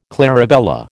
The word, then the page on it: clarabella: American English